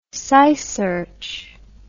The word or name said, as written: SciSearch